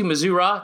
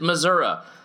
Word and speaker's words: Missouri: And you come back anytime you want to, Missouri. Just don't pronounce it Missouri, because there's few things. That's the GOAT of worst of all time, as people say Missouri instead of Missouri.